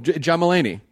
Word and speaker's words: Mulaney: John Mulvaney. – John Mulaney.